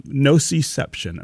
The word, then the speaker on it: nociception: I want you to read from Nociception, and I think I'm pronouncing that the right way, but you'll correct me if I'm not. Did I get it? – I think you got it.